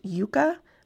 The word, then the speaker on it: Yuka: I think it's pronounced Yuka, but it is spelled Y-U-K-A.